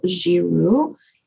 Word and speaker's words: Giroux: On my website, if you go there, which is my name, Isabel Giroux, and you just need to add an N and a D, .com.